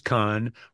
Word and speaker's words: Cannes: It's both. The French is con, regular is can, but there's no S on either of them. It's just can or con.